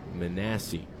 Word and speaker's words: Minacci: Manassi spelled M-I-N-A-C-C-I, but pronounced Manassi.